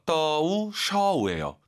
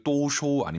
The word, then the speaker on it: 都收: 지금 오유 발음이 연달아 두 번 있죠? 떠우 쇼우예요. 절대 또쇼 아닙니다, 여러분.